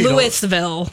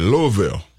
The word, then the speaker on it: Louisville: We were talking Louisville. Let's pronounce it correctly. – When I was down there, we had a family reunion down there when I was like 8, and they were saying Louisville.